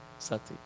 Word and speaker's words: Sati: Sati.